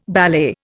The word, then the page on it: ballet: Indian